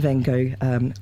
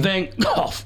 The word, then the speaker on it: Van Gogh: But Van Gogh. – It's like you're clearing your throat, like the Dutch say it.